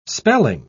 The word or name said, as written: spelling